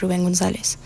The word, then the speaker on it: Rubén González: And then we started off the set with La Engañadora by Rubén González.